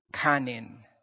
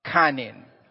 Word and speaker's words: kanin: The word for rice in Filipino, the, the cooked rice, right, is this word. Kanin. Can you guys say that with me? One, two, three. Kanin. Okay, that's what that's that's how you say it.